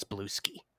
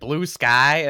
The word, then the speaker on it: Bluesky: It's nice to know that you're another person who knows how to pronounce Blue Ski. – Yes. Thank you. – Because Steven's over here every week. He's like, Blue Sky? And I'm like... Dude, it's one word. It's Blueski.